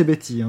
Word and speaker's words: Bette: Mais sinon, je crois qu'il faut prononcer Betty, hein, en fait.